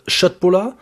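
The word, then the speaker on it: köttbullar: Wir hatten vegetarische Schöttbullar fertig. – Der Herr, ist ein Kenner, er hat es korrekt ausgesprochen.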